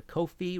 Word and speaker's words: Ko-fi: If you like the show and want to support future episodes, please go to Ko-fi, rhymes with no fee.